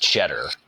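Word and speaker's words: Tschetter: Yeah, I think, and it's not just because the last name is pronounced Cheddar,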